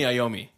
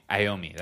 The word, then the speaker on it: Iommi: So Black Sabbath, English rock band formed in Birmingham, 1968, by guitarist Tony, I always forget how to pronounce his last name, Lomi, Bill Ward, and bassist Tony Iommi. – Iommi, that's what it is, yeah.